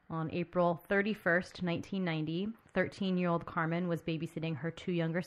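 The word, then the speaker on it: Karmien: It's K-A-R-M-I-E-N. – Mm. – But From the news coverage of this case that I watched, it sounded like her mom was pronouncing it Carmen or something.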